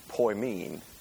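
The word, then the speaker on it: poimen (ποιμήν): And that is a word that is pronounced poimen.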